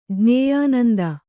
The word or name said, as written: ಜ್ಞೇಯಾನಂದ